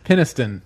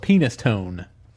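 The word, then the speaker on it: Penistone: There's a place in Great Britain, and it's Peniston, but it's spelled Penis Tone.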